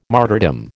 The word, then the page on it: martyrdom: American English